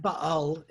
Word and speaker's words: Baal: But because of his scholarship and now the podcast, I'm learning, I mispronounced so many words. I just did. And Baal is how you would say this.